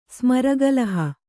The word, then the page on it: ಸ್ಮರಗಲಹ: kannada